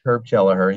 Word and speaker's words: Kelleher: Herb Kelleher.